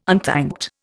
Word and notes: unthanked: Indian

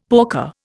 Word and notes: porker: Indian

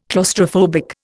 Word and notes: claustrophobic: Indian

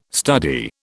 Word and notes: study: British English

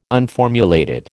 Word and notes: unformulated: American English

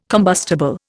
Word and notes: combustible: Indian

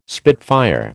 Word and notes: spitfire: American English

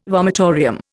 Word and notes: vomitorium: Indian

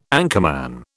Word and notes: anchorman: British English

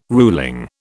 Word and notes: ruling: British English

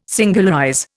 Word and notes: singularize: Indian

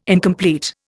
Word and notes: incomplete: Indian

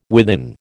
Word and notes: within: American English

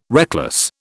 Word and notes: reckless: British English

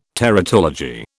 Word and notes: teratology: British English

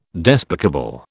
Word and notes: despicable: British English

